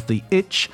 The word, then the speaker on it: ITCZ: Known to sailors around the world, this part of the world is the doldrums, the Intertropical Convergence Zone, ITCZ, pronounced and sometimes referred to as the itch.